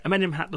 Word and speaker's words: Amenemhat: You correct my pronunciation. Amenemhat and Senwosret. – Yeah, Amenemhat the first and Senwosret the first, yeah. – There you go.